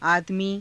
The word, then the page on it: aadamii: Hindi